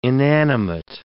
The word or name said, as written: inanimate